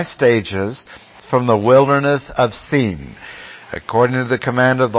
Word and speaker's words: Sin: This has nothing to do with sin. They sinned at Sin, but the word is pronounced Sin.